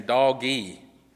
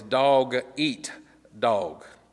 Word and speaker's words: dog-eat-dog: A lot of times people pronounce that wrong. They think it's saying doggy. No, it's dog-eat-dog.